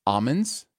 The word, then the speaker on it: almonds: I hate to break the flow of the show, but it's almonds that you do not print. The L is silent.